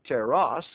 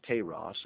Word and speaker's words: teras: Teras, T-E-R-A-S, I think it's teras, actually, is the pronunciation, teras, and it means a prodigy, an omen, or a wonder.